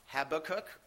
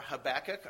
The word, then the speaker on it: Habakkuk: I believe Habakkuk or Habakkuk are both acceptable pronunciations of it.